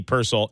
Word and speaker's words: Purcell: Teddy Purcell, excuse me. I always mispronounce it, because it's like you hear different announcers say it different ways. So it is Teddy Purcell.